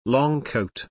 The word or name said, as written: long coat